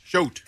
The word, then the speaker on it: Shoat: For their, how do you say it? Shout? Shout? – Shout. – S-H-O-A-T. – Like scrote. – Shout, like scrote.